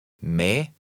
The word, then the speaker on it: med: In number 14, be careful. The E is not followed by a G. And remember that the D at the end is silent. Read number 14. – Me.